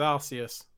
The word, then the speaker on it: Arceus: Yeah, like, I'm glad you said it, because I keep, in my mind, I just see it as Arceus. So I'm glad that's an acceptable pronunciation.